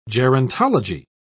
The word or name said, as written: gerontology